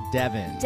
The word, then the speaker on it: Devens: It's pronounced Devens.